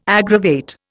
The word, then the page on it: aggravate: Indian